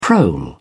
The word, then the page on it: prole: British English